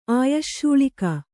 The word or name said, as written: ಆಯಶ್ಶೂಳಿಕ